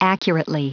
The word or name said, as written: accurately